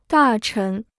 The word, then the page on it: 大臣: dà chén